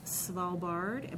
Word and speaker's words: Svalbard: He has recorded in the Arctic region of Svalbard.